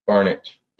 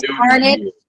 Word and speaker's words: Barnage: And Kristen, how do I say your last name? – Barnage. – Barnage. Got it. Okay. – She's on mute. – Yeah. – You think I know how to use Zoom by now? Yes, Barnage. The E is silent.